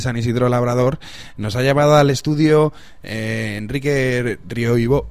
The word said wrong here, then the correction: Roibó: perdón, siempre lo digo mal, le he llamado Roibo, Ribiombo, tal. No. Enrique Roibó,